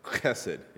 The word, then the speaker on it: chesed: Chesed. Yeah, there we go. You got to get the, if you're not spraying somebody, you didn't say it right.